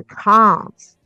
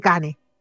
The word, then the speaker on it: Cannes: Esto fue otra de las grandes, eh, de los grandes hitos del Cannes, o el Cannes, ya porque los franceses dicen Cannes, Cannes, Cannes, no sé. Bueno, nosotros en Chile decimos el Cannes, Festival de Canes pero no se dice así,